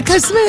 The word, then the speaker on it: Koh Samui: Hang on, is it Koh Samui or Koh Samui? – Yeah, Koh Samui, that's what I said. – Oh, Koh Samui, ah, yes.